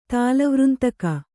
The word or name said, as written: ತಾಲವೃಂತಕ